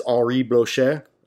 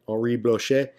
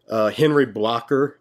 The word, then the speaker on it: Blocher: Another one that is very helpful is Henri Blocher. I think I said that right. Henri Blocher. I think it's a French name. Henry Blocker, if you're a redneck, is what you would say.